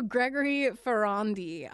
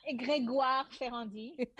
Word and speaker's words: Grégoire Ferrandi: So Gregory Ferrandi. Now you can say it properly. – It's Grégoire Ferrandi.